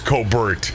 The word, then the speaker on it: Colbert: I know how it's pronounced. – Oh my God! Did he just now catch up? – No, I'm just being proper. – Yeah, thank you. No, proper is Colbert.